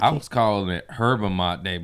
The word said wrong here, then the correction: yerba mate: but all my fans are like, it's Yerba Mate. I'm like, ooh. – Yerba Mate.